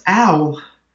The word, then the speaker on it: OWL: And that's the web ontology language, WOL. It's pronounced OWL.